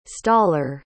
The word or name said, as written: staller